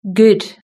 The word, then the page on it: good: British English